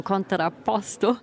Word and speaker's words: Contrapposto: Ja, du hast ihn auch so wunderschön italienisch gerade ausgesprochen, Contrapposto.